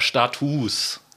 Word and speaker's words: Status: Aber jetzt steht hier im Duden noch drinne, ähm, ein Unterschied besteht lediglich in der Aussprache im Plural, genau, im Plural wird das U lang ausgesprochen. So, und dagegen wehre ich mich ja noch viel mehr. Status.